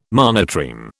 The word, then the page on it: monotreme: British English